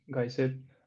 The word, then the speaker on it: Geyser: Y, dime si lo pronuncio bien, es Geyser, ¿correcto? – Sí, correcto, es Geyser.